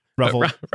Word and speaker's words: Ravel: Ravel. – Ravel's good. Rhymes with Gravel.